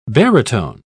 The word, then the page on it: barytone: British English